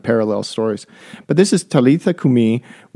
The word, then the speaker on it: Talitha kumi: Is that correct pronunciation? – Talitha kum. Yeah, Talitha kumi. – Nice. – There's usually an accent on the last syllable of Semitic words.